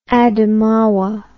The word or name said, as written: Adamawa